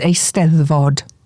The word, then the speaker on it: Eisteddfod: The only Welsh word I know is Eisteddfod." And she pronounced it perfectly.